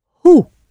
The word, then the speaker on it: hoe: The word for how is hoe. Hoe. H-O-E. Hoe. So not who, but how.